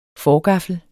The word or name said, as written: forgaffel